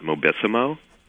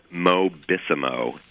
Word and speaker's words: Mobissimo: I would also look at Mobissimo, which is a... – How do you spell that? – Mobissimo. M-O-B-I-S-S-I-M-O.